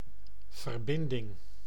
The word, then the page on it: verbinding: [vǝɾ.bɪn.dɪŋ]